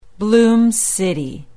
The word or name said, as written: bloom city